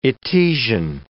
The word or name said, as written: Etesian